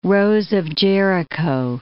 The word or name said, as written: rose of Jericho